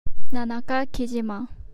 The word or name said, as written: Nanaka Kijima